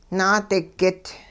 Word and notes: naadigit: hupa

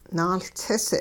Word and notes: nahLtsis: hupa

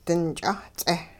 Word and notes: dinch'aat-ts'eh: hupa

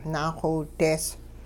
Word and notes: naaxowidees: Hupa

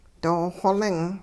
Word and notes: doo-xoling: hupa

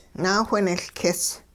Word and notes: naawhinehLkis: hupa

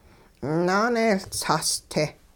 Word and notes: naaneeyLtsaas-te: hupa

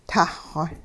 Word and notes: tahxw: hupa

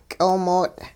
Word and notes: ky'oomoot': hupa

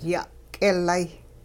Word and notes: ya'k'ilay: Hupa

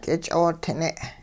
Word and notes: k'ich'ootine: hupa

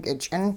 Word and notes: kich'int: hupa

